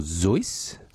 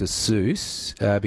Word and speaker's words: Seuss: Which, so, what would the actual correct pronunciation, from what I understand, is Dr. Seuss or Zeus, but he got around to people calling him Dr. Seuss because it rhymed with Mother Goose.